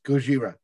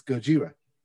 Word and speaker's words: Gojira: And I believe it is pronounced Gojira. – I'm yeah, not just doing like a Godzilla. – Just like the Japanese thing. Name of Godzilla. Yes. – Yes. It's Gojira.